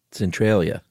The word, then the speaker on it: Centralia: Centralia.